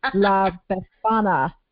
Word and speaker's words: La Befana: I'm trying to remember because I even looked up the pronunciation, but that was last night. I should have done this morning, but it's La Befana.